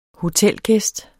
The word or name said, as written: hotelgæst